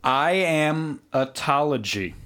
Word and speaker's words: iamatology: Iamatology. Yeah, that's it. That's how you pronounce it.